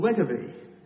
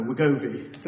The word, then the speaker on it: Wegovy: Now, Novo Nordisk's share price has roughly tripled in the last 3 years, largely because of a new weight loss drug called Wegovy. No, Wegovy.